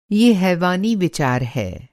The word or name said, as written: हैवानी